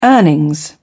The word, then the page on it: earnings: British English